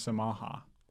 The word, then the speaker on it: Samha: Why does your name sound like Yamaha? – Well, it doesn't, because if you say it wrong, it does. – That's Samha, Yamaha. That's literally the same thing. – Okay, well, it's spelled the same. It still isn't my name.